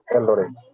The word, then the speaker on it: Eldoret: How do you say that? – Yes, exactly. Eldoret.